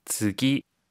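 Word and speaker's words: 쯔기 (次): 쯔기. – 버금차라고 하는 한자를 사용해서 쯔기라고 읽습니다.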